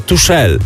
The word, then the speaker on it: Tuchel: A ty wtedy powiedziałeś, że on się wymawia Tuchel.